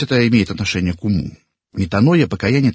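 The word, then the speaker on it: метаноя (μετάνοια): По-гречески покаяние произносится метаноя. И буквально переводится как перемена ума.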